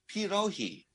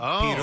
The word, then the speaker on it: pierogi: Do some people use a soft G? Do they say pierogi? – No, they don't. It's like pierogi. – Pierogi. – Oh, pirohi.